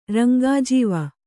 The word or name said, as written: ರಂಗಾಜೀವ